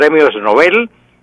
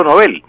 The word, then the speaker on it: Nobel: Porque el creador de estos premios es un señor de nombre Alfredo Nobel, un sueco, Alfredo Nobel. Y el apellido en sueco es Nobel, no Nobel.